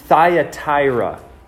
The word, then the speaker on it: Thyatira: Well, tonight we're looking into Jesus' letter to the church in Thyatira, is how you say it.